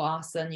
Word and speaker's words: asana: Asana or asana, you can pronounce it either way, is just one more practice under the umbrella.